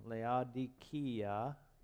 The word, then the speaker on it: Laodicea: In Revelation chapter 3, verse 14 through 22, we have this church at Laodicea, is the proper pronunciation, Laodicea, that we all probably recognize as the lukewarm church.